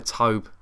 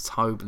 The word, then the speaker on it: Tobe: I think we say Tobe. I think it's Tobe.